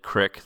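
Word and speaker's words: creek: I do know people who pronounce it Crick, though, so That's a thing. That was mostly in parts of Massachusetts where I grew up, but I'm sure people where I live now probably would also pronounce it as such.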